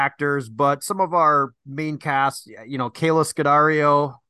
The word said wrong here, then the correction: Scodelario: or Scodelario, I don't know. Don Anelli knows how to pronounce it. He's Italian. Scodelario.